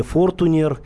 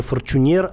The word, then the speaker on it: Fortuner: Не Fortuner, Э, не Фортунер, не Форчунер, а именно Форчунер.